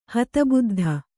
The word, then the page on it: ಹತಬುದ್ಧ: Kannada